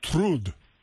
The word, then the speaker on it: Trud: Trud. Man, uh, yeah, I couldn't, but it it, it transliterates transliterates as T R U D. – T R U D. T R U D. Trud.